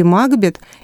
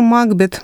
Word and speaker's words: Макбет: Имя Макбета в соответствующих строках читается иначе. Барабанов слышен бой — это Макбет, наш герой, и «С тобой же, Макбет, мы поспорим насмерть, или меч в ножны вложу я без удара». То есть во времена Лескова Макбет произносилось именно как Макбет.